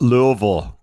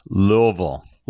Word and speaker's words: Louisville: It's pronounced Louisville, kids. Louisville.